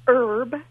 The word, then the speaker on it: herb: If the H is silent, like our or herb, then we use an in front of it.